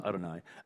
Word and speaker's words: Adonai: And sometimes it's just a phrase, It just um, uh, Yahweh, or they, they, it would be written Yahweh in the Old Testament, but it would pronounce, be pronounced Adonai.